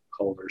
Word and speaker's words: Culver: My name is spelled, so it's a little bit different, Lauren, L-O-R-I-N, and last name Culver, C-U-L-V is Victor, E-R.